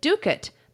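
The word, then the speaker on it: dovecote: It was gorgeous, and she pronounced it Ducat, but it's spelled like Dovecot.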